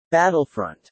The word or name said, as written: battlefront